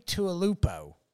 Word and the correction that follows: Tuipulotu: Tui Tuipulotu. That's how you pronounce it, yeah.